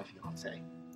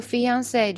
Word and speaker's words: fiancé: Penny is my fiance. – 好，他说呢，一定有什么误会。 Penny 是我的 fiance，就是我的未婚妻。那么大家呢，听这个 fiance 这个词呢，它的呃这个发音的调调哈，跟英语就有那么一点点不同啊，拼写呢就更不一样了。它那个。